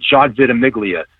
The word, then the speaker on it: John Ventimiglia: John Ventimiglia.